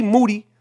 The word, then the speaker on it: Moody: And if you're in the five boroughs, this happened right there in Brooklyn on Bushwick Avenue, not too far away from G. Moody. Last name rhymes with duty, where he grew up in the Brownsville section of Brooklyn.